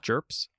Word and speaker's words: GURPS: Yeah, well, actually, the G stands for graphical, so it's actually pronounced GURPS.